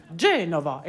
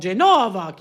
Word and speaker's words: Genova: À la spéciale, l'annonce disait bien « Genova », ce qui est la façon italienne de désigner la ville de Gênes, avec l'accent sur la première syllabe « Genova ». J'ai toujours beaucoup insisté auprès de mes chanteurs pour qu'ils respectent les accents toniques. Genova, et non Genova, qui évidemment, en français, donnerait Genève.